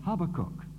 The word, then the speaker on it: Habakkuk: Then he said, if I were to pronounce this in Hebrew, I should say Habakkuk.